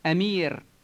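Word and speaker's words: emir: The word for prince is Amir.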